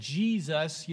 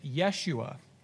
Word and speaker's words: Yeshua: Um, uh, Jesus' name in Hebrew uh, if you lived in, you know, Israel 2,000 years ago and you said Jesus, you know, no one would yes. say it like that phonetically. Uh, they would have pronounced it Yeshua.